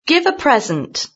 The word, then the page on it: give a present: British English